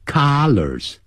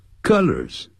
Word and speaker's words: colors: 所以 this t-shirt comes in three colors，哎，颜色不要念成 colors，不是 a 的音，是 a 的音，肚子 a 的 a， colors， colors 那是 c-o-l-l-a-r 加个 s，这是代表多数，要衣领，领子。